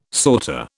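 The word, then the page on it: sauté: British English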